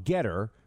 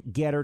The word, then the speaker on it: Gettr: On Getter, pronounced G-E-T-T-R, or rather spelled G-E-T-T-R, pronounced Getter.